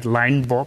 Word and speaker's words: Linebaugh: Chama-se o livro, chama-se Ned Ludd e Rainha Meb de um historiador americano que deve-se pronunciar Linebaugh, Peter Linebaugh,